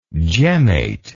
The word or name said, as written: gemmate